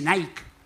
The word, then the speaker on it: Nike: Y me dice, no se dice Nike, se dice Nike. Pero en la caja dice Nike. Sí, pero la I se pronuncia ay.